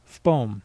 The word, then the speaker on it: fpom: So for example, there is a word FPOM. You may try to say this as FPOM, but that is making an extra syllable out of it. It's just one syllable. It is pronounced FPOM.